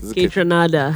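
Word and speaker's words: Kaytranada: Ketranada.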